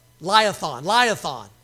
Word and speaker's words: Leviathan: Things how you say it. Leviathan. Yeah, exactly. That's what I said.